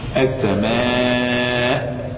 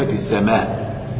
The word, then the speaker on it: السماء: نعم السماء السماء. هل كلمة السماء تنطق من غير الهمزة؟ لا، لا بد من الهمزة، فهي من أصل الكلمة لا بد من الهمزة.